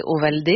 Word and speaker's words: Ovaldé: Oui, mais parce qu'en fait, en français, si vous écrivez, ça s'écrit O-V-A-L-D-E, tout le monde prononce ça Ovalde. – Et c'est pas bien ça n'a pas tout à fait quand même la même musique. Et mon père m'a toujours dit qu'on s'appelait Ovaldé.